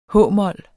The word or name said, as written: h-mol